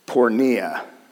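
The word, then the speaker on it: pornea (πορνεία): It looks like this, and it's pronounced pornea. Pornea.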